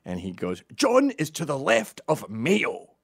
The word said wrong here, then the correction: Mao: instead of Mao. You pronounced Mao Mayo, something I thought about for 24 hours straight afterwards.